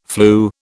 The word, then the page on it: flew: British English